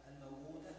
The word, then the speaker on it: الموؤودة: على قصر الواو الأولى التي بعد في الموؤودة في سورة التكوير، وقصر واو موئلا في سورة الكهف، والمقصود بقصر الواو في هذه الكلمات الثلاث سوءات الموؤودة موئلا النطق بواو من غير مد مطلقة مثل النطق بواو فوق.